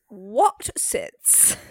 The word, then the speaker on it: Wotsits: Okay, I just want to be clear for everyone who can't see this, that Watsits is spelled W-O-T-S-I-T-S. Watsits. – Watsits.